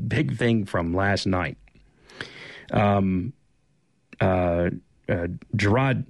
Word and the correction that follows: Jarrod: Jared, excuse me, I think it's Jared is how he pronounces it.